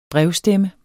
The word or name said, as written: brevstemme